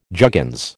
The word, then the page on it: juggins: American English